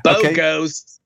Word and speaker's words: Bogost: It's Play Anything by Egan Bogost. – Why did you say it that way? – Because that's because that's how you say it. Bogost.